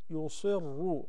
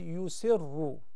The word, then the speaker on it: يصروا: Just a little observation in ayah number 8, the word يصروا. And it's common because it is followed by the the letter ra, and it is preceded by ya. So sometimes people mispronounce it and they say يسروا.